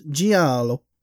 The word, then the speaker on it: giallo: First, it's Italian for yellow, pronounced giallo, excuse the accent.